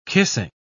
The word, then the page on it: kissing: British English